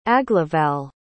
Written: aglaval

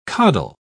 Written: coddle

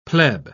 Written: pleb

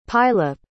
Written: pylyp

